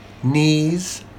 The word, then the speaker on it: knees: In words starting with KN, the K is silent. Examples, no, knees, and knife.